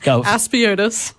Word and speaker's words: Aspiotis: Aspiotis. – Aspiotis. See, I wanted to put the emphasis on the wrong syllable and then I couldn't say it.